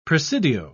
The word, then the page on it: presidio: British English